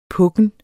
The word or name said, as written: pukken